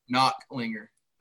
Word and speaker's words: Knocklinger: Zane Knocklinger? – Knocklinger. – Knocklinger. See, I knew I wasn't doing it right.